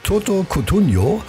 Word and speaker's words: Toto Cutugno: So, und am allerliebsten, schreibt die Corinna, hört sie von Toto Cutugno. Habe ich das richtig ausgesprochen? Das ist ja Italiener. Oh, das hast du fantastico ausgesprochen, aber es war Umberto Tozzi.